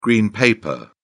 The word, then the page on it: green paper: British English